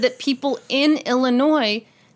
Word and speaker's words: Illinois: There's been a large number of people who have reached out to us and said that, yes, in fact, people do pronounce the S on Illinois all the time.